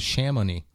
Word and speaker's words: Chamonix: She's told me it with an R, Charmonie, N-I-X, and I've seen it Chamonix. Or there's more place like on the internet with Chamonix. – Chamonix. That's how I know you pronounce it. – Well, she told me an R, and I looked it up an R, and I saw a couple that showed Chamonix. And that sounds more like it would be to me.